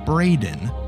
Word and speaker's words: Braden: Also, while we're on the topic of mispronunciations, a concerning number of our listeners seem to believe Braden, spelled B-R-A-D-E-N, is pronounced Brandon or Brendan.